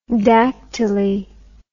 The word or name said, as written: dactyly